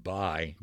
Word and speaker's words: Bui: So the camp commander, Major Boo, I think his name was, correct? – B-U-I. We pronounced it Bye.